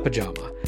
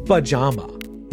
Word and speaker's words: pajama: pajama. That's a type of clothing that I've worn many, many times in my life. And it's pronounced like that, pajama, not pajama.